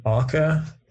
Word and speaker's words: oca: Aca is another one. It's spelled OCA, not to be confused for okra.